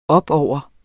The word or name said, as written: opover